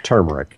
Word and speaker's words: turmeric: It's turmeric. – Turmeric. – Yeah. Mm-hmm. It's got an R in it, I promise you. – You think? – An extra one, okay.